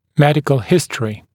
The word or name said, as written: medical history